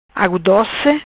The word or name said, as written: Agu doci